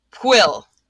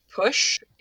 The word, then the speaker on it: Pwyll: P-W-Y-L-L. Pwyll Pen Annwn. – Okay. – Right? That's a good one. Somebody made it sound like push in a video I was watching.